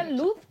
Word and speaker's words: Looz: Se pronuncia luz, pero se escribe L-O-O-Z.